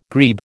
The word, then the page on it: grebe: American English